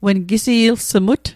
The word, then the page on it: ilsumatl: Mi'gmaq